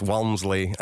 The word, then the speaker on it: Walmsley: Pronounced Warmsley, but he's got an L in there as well somewhere. – Wait, my dad would fight that because he's trying to tell me now that it's Walmsley, and I'm like, you can't just change your name when we pronounce our name, you know, after all these years.